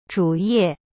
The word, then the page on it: 主页: zhu ye